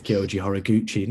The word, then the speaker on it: Kyoji Horiguchi: Sergio Pettis is in action there against, I can never pronounce his name, but I'm going to butcher it. It's Kyoji Horiguchi. No, I nailed it, actually.